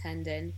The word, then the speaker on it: Hendin: And my last name's Hendin, H-E-N-D-I-N.